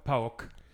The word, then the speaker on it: PAOK: Det, det er sådan, man udtaler det på men, græsk. PAOK.